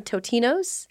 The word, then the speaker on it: Totino's: Cosmic Salmon says, Tostino's party pizzas are not pizza, but what they are is good. I think they're saying Totino's. – Yeah, Totino's is the party most pizza. Mispronounced brand of all time.